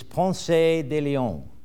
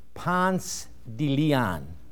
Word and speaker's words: Ponce de León: For if you do use that, you'll be immediately identified as an outsider and definitely not a local, for the locals pronounce that town Ponce de León. Somebody would faint in Spain. This Spanish student back here is holding his head. Ponce de León.